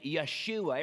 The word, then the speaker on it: Yeshua: Which is the transliteration of a Hebrew name, Yeshua. Everybody say Yeshua. Yeshua or Jehoshua. Or the English pronunciation is Joshua. It's Joshua.